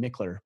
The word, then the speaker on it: Michler: And then on social media, the place I'm active most is on Instagram at Ryan Michler. My last name is spelled M-I-C-H-L-E-R.